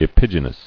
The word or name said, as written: Epigynous